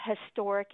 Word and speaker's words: historic: This has been another one of my hobby horses, because historic does not begin with a vowel sound. it begins with a consonant sound, like a bird or a shoe, a historic event.